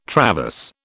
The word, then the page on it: traverse: British English